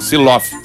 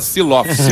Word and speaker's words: Zulow: Não é Slow, não é, é Vilso o nome do violeiro e o nome dele é outra pronúncia. Siloff. Se escreve Z-U-L-O-W. – Que susto me deu. – Se fala Siloff.